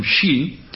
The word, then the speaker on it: shi: En la cultura japonesa, el número 4 se considera de mala suerte porque su pronunciación, shi, es similar a la palabra que significa muerte.